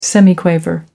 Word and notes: semiquaver: American English

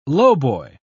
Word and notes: lowboy: British English

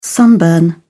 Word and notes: sunburn: British English

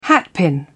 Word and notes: hatpin: British English

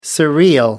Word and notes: surreal: American English

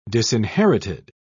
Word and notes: disinherited: British English

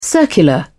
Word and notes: circular: British English

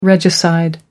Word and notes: regicide: American English

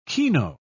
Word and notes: keno: British English

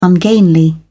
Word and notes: ungainly: British English

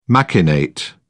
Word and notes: machinate: British English

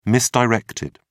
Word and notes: misdirected: British English

